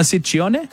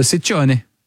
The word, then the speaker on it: Ciccone: Well, in Italian, it works the other way. So the double C's are hard C's and the singular C's are soft C's. – So Ciccone.